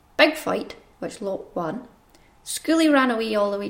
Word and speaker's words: Skuli: That's spelled S-K-U-L-I, and Lot is L-G-O-T, but this is how I'm saying it.